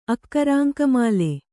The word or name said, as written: ಅಕ್ಕರಾಂಕಮಾಲೆ